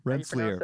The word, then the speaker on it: Rensselaer: Rensselaer. Is that how you pronounce it? – Rensselaer. You're close. – Rensselaer.